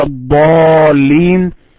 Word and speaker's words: الضالين: And when you say ولا الضالين, you have to prolong the pronunciation after you hit ضاد to 6 times.